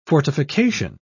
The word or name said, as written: fortification